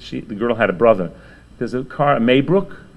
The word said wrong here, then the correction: Maybach: am I pronouncing it right? Yeah? Maybach, thank you.